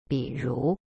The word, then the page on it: 比如: bi ru